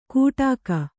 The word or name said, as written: ಕುಟಕ